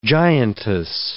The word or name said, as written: giantess